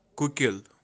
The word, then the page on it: Kukil: Kashmiri